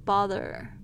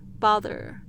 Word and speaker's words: bother: Number two, bother bother b o t h e r，大家特别注意一下你们 t h 的发音，嗯，是轻轻咬住舌尖发出了这个有声的声音，嗯，bother，OK，Number three，counsel，counsel，还是一样要注意 o u 的发音，不要念成 counsel，counsel，它是 l 的声音哈，它是一个双元音，counsel 咨询，c o u n s e l。